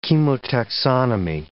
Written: chemotaxonomy

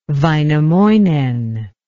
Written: vainamoinen

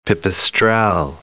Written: pipistrelle

